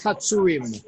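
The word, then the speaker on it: Hazzurim: I can't pronounce that. Helketh Hazarim? – Helketh Hatsurim. – Hatsurim, okay.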